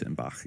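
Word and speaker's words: Eschenbach: Yeah, well, particularly on one iteration of that myth, which is Eisenbach's poem, Wolfram von Eisenbach, or Eisenbach. I hope, again, I'm saying that right. – But I think it's Eisenbach, yeah.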